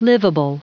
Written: livable

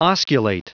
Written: osculate

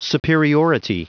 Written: superiority

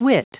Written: wit

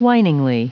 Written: whiningly

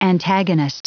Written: antagonist

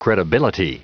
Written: credibility

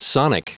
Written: sonic

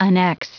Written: annex

